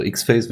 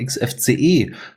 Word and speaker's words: XFCE: Und da kommen wir sofort zu den Desktop-Interfaces, nämlich X-Face, also X-Face wird es nicht ausgesprochen, sondern XFCE 4.20 ist veröffentlicht worden mit einigen sehr sinnvollen Neuerungen, nämlich unter anderem experimenteller Unterstützung für den Wayland-Anzeigeserver.